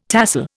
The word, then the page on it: tahsil: Indian